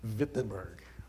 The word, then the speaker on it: Wittenberg: In German, it's always a V. It's when it's a W, they always pronounce it with a V. Wittenberg.